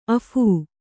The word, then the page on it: ಅಫೂ: Kannada